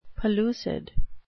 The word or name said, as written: pellucid